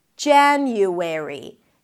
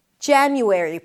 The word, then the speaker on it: January: January. January. Primary stress is on the first syllable.